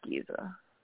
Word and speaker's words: Giza: Yeah, it's Giza. Okay, yeah. Okay, I did say that right.